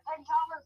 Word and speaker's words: Pantala: Palanta. Or was it Pyria? I think it was Palanta. Or however you pronounce it. – Pantala's the one with no trees.